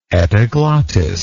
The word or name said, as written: epiglottidean